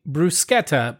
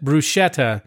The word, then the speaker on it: bruschetta: But interestingly enough, when an Italian word has a CH, that's actually pronounced like a K. That's right. So, it's a hard C. – So one of my pet peeves, when you say bruschetta, but you pronounce it bruschetta, I slowly die inside.